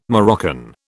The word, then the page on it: Moroccan: British English